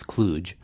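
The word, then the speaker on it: kluge: I'm not sure how he pronounces it, but the word that I'm using, which is an old engineer's bit of slang, we in this country pronounce kludge. Brits, I am told, pronounce it kludge. But I like to rhyme it with stooge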